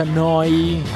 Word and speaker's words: Neu: Neuradio.it si scrive Neu, si pronuncia Noi, e vuol dire nuova emittente urbana da Bologna.